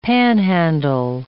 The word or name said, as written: panhandle